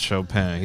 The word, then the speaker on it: Chopin: He probably just found out now it's pronounced Chopin.